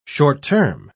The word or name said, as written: short-term